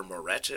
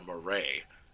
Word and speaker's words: Moret: like i had roger moret in there or roger moray in baseball reference, he, they pronounce it. It says it's pronounced Moray, but the announcers always pronounce it Moret.